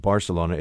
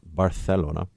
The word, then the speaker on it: Barcelona: If you're one of the cool folks who's visited Spain and knows your lingo and knows the different pronunciations, it's not Barcelona, it's Barcelona.